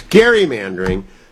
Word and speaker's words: gerrymandering: One is gerrymandering is routinely mispronounced. It's gerrymandering, but I'm a scold when I bring that up.